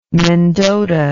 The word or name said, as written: mendota